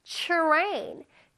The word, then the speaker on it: train: 근데 트레인 말고 선생님이 앞에 무슨 소리를 내주면 더 자연스럽고 멋있어진다 그랬지? 그쵸? 추 소리를 내주면 좋아요. Train, train. 뭔가 자연스럽지? 그냥 train, train보다 train이라고 우리 친구들은 멋있게 발음해주세요.